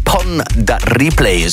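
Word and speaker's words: Pon de Replay: ons Rihanna met Pon da Replay. Zo moet je dat blijkbaar uitspreken.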